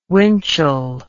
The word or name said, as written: Winchell